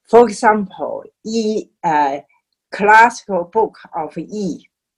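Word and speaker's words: Yi: So the pronunciation called the I, actually in the real Chinese pronunciation should be E. It's like a Y-I-E.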